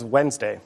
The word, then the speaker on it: Wednesday: Shakespeare's first folio spells this day of the week as it's said, without the first D. – What is Wednesday? – Yes.